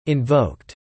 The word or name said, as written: invoked